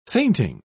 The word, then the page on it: fainting: British English